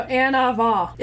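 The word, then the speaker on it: anavah: So Anava is how you say it in Hebrew.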